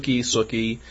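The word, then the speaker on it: Sookie: Suki. It's spelled Sooki, like cookie. – Yeah. – But with an S.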